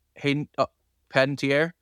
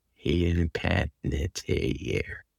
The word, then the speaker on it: Hayden Panettiere: Hayden Panettiere. – Okay. Hayden Panettiere.